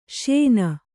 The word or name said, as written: ಶ್ಯೇನ